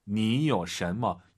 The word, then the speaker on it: 你有什么: 니요 샨머. 여러분, 요는 올라가면 안 되죠. 뒤에가 이성이잖아요. 삼성 이성. 요 샨.